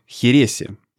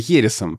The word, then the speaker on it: херес: Чтобы не отходить далеко от вот, различий, в том числе в ударениях, вот моя знакомая, которая живет в Испании, она живет в Хересе. И напиток, который делают там, она называет тоже херес. При том, что, конечно, для русского уха и для жителей России привычнее называть это хересом. Но она, опять же, поскольку она туда переехала, и она там ассимилировалась, да, она произносит херес так, как это произносят все в Испании.